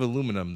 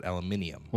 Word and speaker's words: aluminium: Instead of aluminum, they pronounce it aluminium.